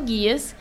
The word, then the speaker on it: Geass: Chama Code Guias, é code de código, né? De C-O-D-E. E Guias é... Aí é como eu pronuncio, porque eu só escuto em japonês, então não sei se tem outra pronúncia. É G-E-A-S-S.